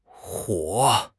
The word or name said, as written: huo3